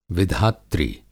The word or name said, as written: विधात्री